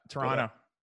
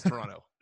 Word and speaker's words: Toronto: In America, we love to emphasize the second T in Toronto. – Yeah, Toronto. – But it's Toronto. You just leave that second T out.